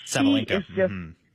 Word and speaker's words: Sabalenka: I really don't think either American woman could beat the machine of, how do you pronounce her name? Sabalenka? – Sabalenka. – Sabalenka, she is on fire.